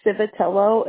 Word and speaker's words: Civitello: So our last name is Civitello. It's spelled C-I-V as in Victor, I-T as in Tom, E as in Edward, L-L-O.